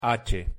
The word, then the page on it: h: Spanish